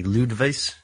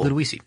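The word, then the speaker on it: Ludowici: Ludowice is not like Ludwice? – No, Ludowice. – Cool. – Ludowice.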